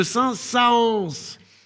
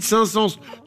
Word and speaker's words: Saint-Saëns: Puisque je le rappelle, le rôle du tréma est de nous signifier que les deux voyelles ou syllabes qui se suivent se prononcent séparément. Donc, logiquement, on devait prononcer Camille de Saint-Saëns. Alors qu'on dit Camille de Saint-Saëns, tout le monde s'en fout, mais c'est mon coup de gueule.